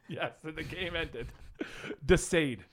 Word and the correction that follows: Desaad: Oh, Desaad.